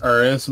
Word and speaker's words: Urus: It's pronounced Urus, but whatever. – Pronounce suck my balls. – I'm gonna pronounce it Urus anyway, so I'm gonna fake a fucking roll on my tongue just to annoy you.